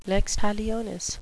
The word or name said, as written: lex talionis